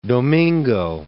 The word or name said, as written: Domingo